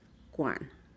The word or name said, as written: kuan